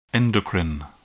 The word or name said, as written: endocrine